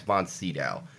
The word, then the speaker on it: von Sydow: I think you keep saying von Sydow. I think his name is pronounced Max von Sydow. That's that's how I've always said it, Max von Sydow.